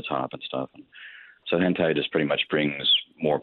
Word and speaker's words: hentai: Hentai? – Yeah, hentai.